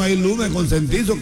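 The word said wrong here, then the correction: concientizo: Consentizo no se dice, se dice conscientizo.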